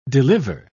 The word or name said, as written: deliver